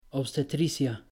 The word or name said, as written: obstetricia